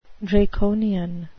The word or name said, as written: draconian